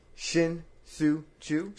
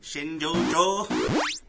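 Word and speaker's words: Shin-Soo Choo: Shinsu Chu. – Shinjojo.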